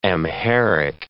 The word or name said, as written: Amharic